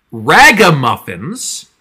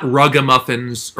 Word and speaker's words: Ragamuffins: The one we have here is episode 59 of the original run, which is called, let me just make sure I'm pronouncing this correctly, Ragamuffins, not Rugamuffins or Runamarink or any of the other things Mark thought it was.